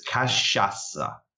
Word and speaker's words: cachaça: So a lot of people have trouble with this, the pronunciation of this word, and I totally understand because I had no idea before I got here. I was like, kakaka, kahaka. So it's kashasa. So kashasa, kashasa.